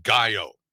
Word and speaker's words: gallo: There you go, because Catherine corrected us. She said gallo.